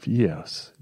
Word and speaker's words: years: Dar e mai engleză britanică zici five years, yes, yes. Un fel de pauză, nu pronunți acel r.